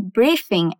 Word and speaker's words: briefing: A pronúncia em inglês foca mais no começo da palavra, mas vamos falar briefing. Briefing. – Let's try repeating. Vamos tentar repetir. Brief. Tipo queijo brie mesmo.